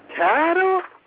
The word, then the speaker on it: chattel: Chattel? They made cattle be slaves too? – No, not cattle, Jabril. All of you children repeat after me. Chattel. Now, it's spelled C-H-A-T-T-E-L.